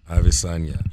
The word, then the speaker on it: Adesanya: Israel Adesanya. – Adesanya.